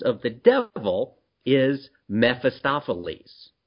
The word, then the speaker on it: Mephistopheles: Mephistopheles. No, Meph. M-E-P-H. – Mephistopheles.